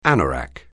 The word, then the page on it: anorak: anglais